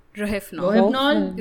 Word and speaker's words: Rohypnol: R-O-H-Y-P-N-O-L. – Rohypnol.